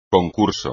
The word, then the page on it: concurso: espagnol